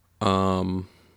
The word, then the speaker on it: Waukesha: Before we move to the Waukesha, is that how you pronounce it? Yeah.